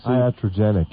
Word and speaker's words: iatrogenic: man-made or accidental man-made disease, I-A-T-R-O-G-E-N-I-C? – Iatrogenic. – Iatrogenic, okay.